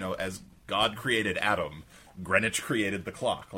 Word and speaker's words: Greenwich: But Bruce Sutphin told us about a place just to the east of Saratoga Springs, a village called Greenwich, where they pronounce it as spelled, and also Puzzle Sister.